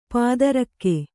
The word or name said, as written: ಪೈತೃಕ